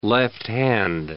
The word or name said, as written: left-hand